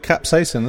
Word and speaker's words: capsaicin: How do you say capsaicin? – Capsaicin. – Capsaicin. – Ah. – Capsaicin. – Capsaicin. Yeah. – That wasn't far off.